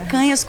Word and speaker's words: Canhas: Canhas, picanha, o cara nunca mais esquece, mas é Canhas com N com tio,